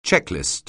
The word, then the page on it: checklist: English